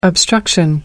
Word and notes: obstruction: American English

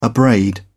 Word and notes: abrade: British English

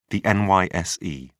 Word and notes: NYSE: British English

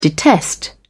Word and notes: detest: British English

